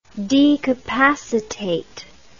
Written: decapacitation

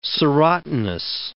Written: serotinous

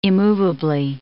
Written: immovably

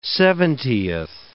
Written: seventieth

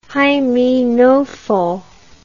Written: hymenophore

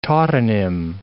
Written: tautonym

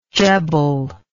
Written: jebel

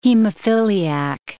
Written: hemophiliac

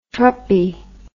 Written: truppie

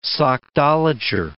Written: sockdologer

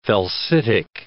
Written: felsitic

